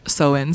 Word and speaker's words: Samhain: And so again, as I mentioned earlier, it originally derived from the ancient Celtic festival, which is actually pronounced Samhain, so do apologize about the earlier discretion.